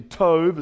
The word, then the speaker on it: Tob: It's actually Tob, is how it's said. Looks like Tob, but it's to Tob, and that's going to come up in the chapter tonight.